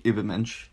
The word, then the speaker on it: Übermensch: Their plan was to transform humanity into the Nietzschean Übermensch. Did I say that right? – Essentially, Übermensch. – Übermensch. That right. Was like French. Uber. – Uber. That's the U with the umlaut. Uber.